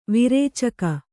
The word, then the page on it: ವಿರೇಚಕ: virēcaka